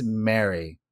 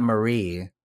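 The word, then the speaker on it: Maree: This is coming at you from, I think it's Mary. That's how you pronounce it, even though it's spelled weird. It's M-A-R-E-E. It's not Marie. I think it's Mary.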